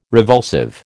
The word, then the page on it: revulsive: American English